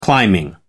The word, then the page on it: climbing: American English